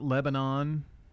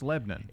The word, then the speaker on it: Lebanon: Uh, in Wilson County, it is Lebanon, Lebanon, Lebanon. Not Lebanon. It's Lebanon. It's it's not pronounced the same way as the ancient city that it is named after. – Yeah. Correct. – It yeah. is pronounced differently.